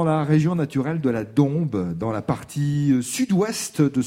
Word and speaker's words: Dombes: Alors Dombes, ça s'écrit avec un S à la fin, mais on dit bien la Dombes.